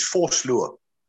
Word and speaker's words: Vosloo: For instance, myself here, my surname is Vosloo, spelled V-O-S-L-O-O.